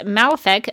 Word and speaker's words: Malefa'ak: Malefic, I should mention that it is spelled MALEFA'AK, in case anyone was wondering.